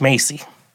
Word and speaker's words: Macy: Macy, which actually does rhyme with John Wayne Gacy.